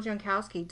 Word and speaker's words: Jankowski: Bill Jankowski. Did I say your last name right? I'm sorry, Bill. I didn't check first. – Yeah, absolutely perfect.